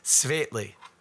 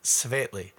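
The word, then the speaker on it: Světlý: Sorry, one of the people who will remain nameless, but who is a native Czech speaker, helped me with my pronunciation. It's Svetly, I believe is how you're supposed to say it. Svetly.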